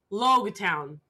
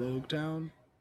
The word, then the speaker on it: Logue Town: Logue Town.